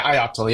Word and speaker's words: ioctl: Yeah, Ioctl, yeah.